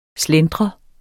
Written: Slentrer

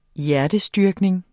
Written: HJERTESTYRKNINGERNES

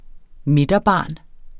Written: midterbarn

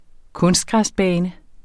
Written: kunstgræsbane

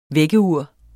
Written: vækkeur

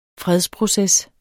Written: fredsproces

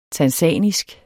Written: tanzanisk

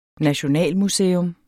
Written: nationalmuseum

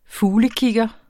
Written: fuglekigger

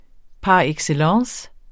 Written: par

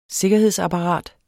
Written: sikkerhedsapparat

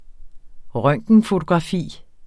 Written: røntgenfotografi

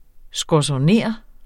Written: skorzoner

